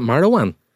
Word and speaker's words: Marouane: Well then Owen gets it off Marouane Fellaini. – Yeah, I'd go with that then, yeah. Owen getting battered by Marouane Fellaini, a few pencils. – Is it Marouane? – Yeah.